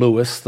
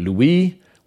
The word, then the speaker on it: Louis: St. Louis was named after King Louis IX of France. The French pronounce it Louis, we pronounce it Louis.